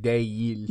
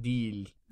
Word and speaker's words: değil: Turkish people don't really say değil. – No. Değil, no. Değil. – Değil. – Uh-huh. – So it's really more like a D-I. – A long I. – Deal. – Deal. – Not değil. So deal.